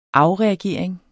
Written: afreagering

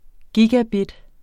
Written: gigabit

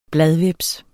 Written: bladhveps

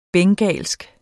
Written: bengalsk

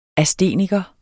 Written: ASTENIKER

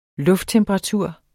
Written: lufttemperatur

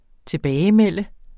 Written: tilbagemelde

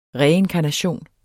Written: reinkarnation

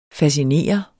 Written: fascinere

